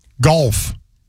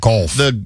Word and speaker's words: Gulf: Gulf, not golf.